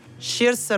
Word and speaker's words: Saoirse: Mi hanno detto, quanto ne so, si pronuncia Saoirse Ronan.